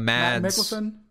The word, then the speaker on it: Mads Mikkelsen: Mads. – Mikkelsen. Mads Mikkelsen. I think that's how it's pronounced.